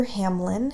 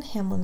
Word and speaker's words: Hamlyn: It's called Dear Hamlin. Hamlin is spelled H-A-M-L-Y-N.